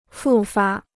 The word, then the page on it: 复发: fù fā